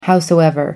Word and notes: howsoever: American English

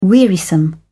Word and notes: wearisome: British English

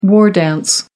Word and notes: war dance: American English

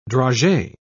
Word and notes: dragee: British English

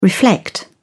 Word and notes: reflect: British English

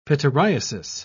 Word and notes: pityriasis: British English

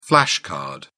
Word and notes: flash card: British English

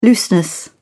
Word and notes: looseness: British English

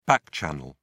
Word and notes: back-channel: British English

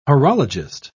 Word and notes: horologist: British English

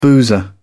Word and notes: boozer: British English

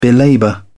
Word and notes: belabour: British English

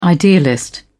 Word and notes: idealist: British English

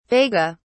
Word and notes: vega: British English

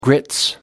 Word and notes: grits: British English

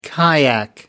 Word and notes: kayak: American English

